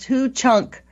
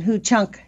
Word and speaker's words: Ho-Chunk: Bill Greendeer is a tribal member of the Hukuk Nation, pronounced Hu-Chunk, in Wisconsin, Ho-Chunk Nation.